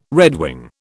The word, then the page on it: redwing: British English